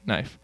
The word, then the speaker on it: knife: But now we don't pronounce it knife. We just say knife. We don't pronounce it knight. We just say knight.